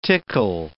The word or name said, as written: tickle